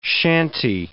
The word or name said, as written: shanty